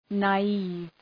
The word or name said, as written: naive